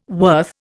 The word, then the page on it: worth: Indian